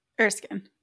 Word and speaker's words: Erskine: Erskine.